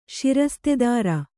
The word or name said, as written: ಷಿರಸ್ತೆದಾರ